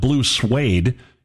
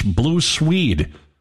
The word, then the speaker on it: Blue Swede: It's not Blue Suede, it's Blue Swede.